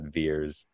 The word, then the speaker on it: Vers: And so V-E-R-S, they pronounce that Veers.